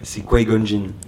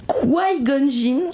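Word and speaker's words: Qui-Gon Jinn: Sauvé par King Jong-jin et son padawan... – King Jong-jin. – Pourquoi ça se prononce pas comme ça? – C'est Qui-Gon-jin. – Qui-Gon-jin.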